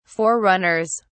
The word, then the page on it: forerunners: British English